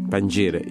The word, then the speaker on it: Pangira: Pangira, did I pronounce that right? – Pangira, yes, Pangira.